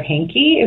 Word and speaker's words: Heinicke: And the fact that we had to have seen this combination of Tom Savage, TJ Yates, and very briefly, Taylor Heinicke, is that how we say his name? – Sure.